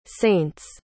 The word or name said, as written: saint's